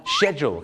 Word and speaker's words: schedule: ชาวอังกฤษเนี่ยนะครับจะออกเสียงไม่เหมือนอเมริกาครับอเมริกาจะคล้ายๆของคนไทยครับก็คือ schedule ครับแต่ปรากฏว่าชาวอังกฤษหลายคนมากนะครับก็จะออกเสียงว่า schedule ครับผมชอช้างนะฮะ schedule นะฮะคล้ายๆชอช้าง she นะฮะ she schedule นะฮะ